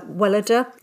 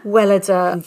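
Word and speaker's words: Weleda: Or if I'm going to treat myself, I like Wellida. That's how you pronounce it. Wellida.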